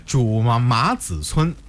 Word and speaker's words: 朱麻子村: 那看来我这个读音没有错哈，这个名字应该是朱麻子村啊，这个如果换成现在这个姓的话应该是朱麻子，也是这个意思对不对？ – 对。 – 看来我这个发音还没有发错哈。